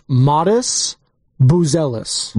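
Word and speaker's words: Matas Buzelis: The Bulls guy's pronounced Modus Buzelis.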